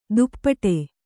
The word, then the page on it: ದುಪ್ಪಟೆ: duppaṭe